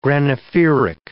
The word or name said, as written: granophyric